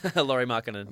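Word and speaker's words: Lauri Markkanen: Chicago gets Zach LaVine, Chris Dunn, pick 7, turning to Makarin. I bet that's how you pronounce his name. – Laurie Markkanen. – Laurie Markkanen, excuse me, Laurie.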